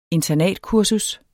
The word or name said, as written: internatkursus